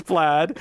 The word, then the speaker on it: plaid: I always want to say plaid because that's how it's spelled. – Yeah, and I'm like, that's not how it's said. It's plaid. Why wouldn't it be P-L-A-D?